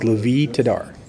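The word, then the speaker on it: Lavie Tidhar: If I remember correctly, it's Levi Tadar. – Levi, yes. – Tadar. Okay. I think I had the last name right, but I've got the first name wrong.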